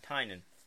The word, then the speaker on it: Tynion: Am I saying that right, Tynion? – Tynan. Yes, Tynan.